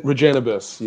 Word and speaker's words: Regennabis: And and we're going to be dis- discussing Regennabis. Is that how they pronounce uh, the company or how does your company pronounce and what is it? – That's it, Regennabis. You're absolutely right.